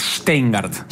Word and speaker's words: Stengart: Es Stengart. – Stengart. – Es un sh, después suena como suena.